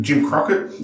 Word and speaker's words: Jim Crockett: I'm probably going to get this name wrong, but you can, Jim Crockett, yep. – Is that right? – Yep.